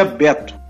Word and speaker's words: Beto: Eu brinco porque eu, ou eu sou chamado de Beto ou de Mussa. Alberto, eu digo que é, um, é, se escreve Alberto, mas se pronuncia Beto.